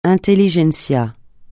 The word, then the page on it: intelligence: francais